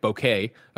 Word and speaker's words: bokeh: An anobiologist says bokeh is pronounced like bouquet, bouquet, like a bouquet of flowers, bouquet.